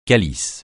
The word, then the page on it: calice: francais